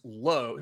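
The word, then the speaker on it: Lowe: Now Josh is pronounced Low. It's Josh Low, Brandon Lau.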